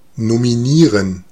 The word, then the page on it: nommer: France; [nɔ.me]